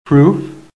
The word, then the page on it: PROOF: American English